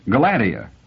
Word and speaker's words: Galadia: Her name is Galadia. Brewer pronounced it in three syllables, accenting the second.